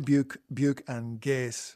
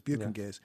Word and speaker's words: Buke and Gase: Buke and Gase, because it could be Buke and Gas, but it's Buke and Gase.